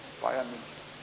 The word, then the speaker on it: Bayern München: Oh, is that how you pronounce it? – Yes. Bayern München.